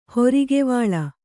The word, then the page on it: ಹೊರಿಗೆವಾಳ: horigevāḷa